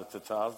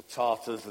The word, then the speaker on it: Tatar: Now, you all know about the Tatars, don't you? Okay, they're a people group that used to rule Russia at one time, the Mongol Tatar Empire, or Tartars, as the English used to pronounce it.